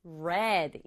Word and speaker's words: read: 보기는 I read처럼 보이지만 read, 읽었다 이렇게 발음해주고요.